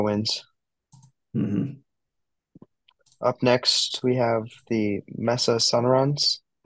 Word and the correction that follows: Sonorans: Did I pronounce that right? – I think maybe it's the Mesa Sonorans, Okay.